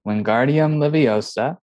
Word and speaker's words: Wingardium Leviosa: Wingardium Leviosa, Expelliarmus, Petrificus Totalis.